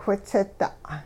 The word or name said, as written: whitsida'